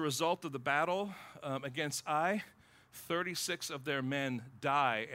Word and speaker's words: Ai: A-I, it's pronounced Ai.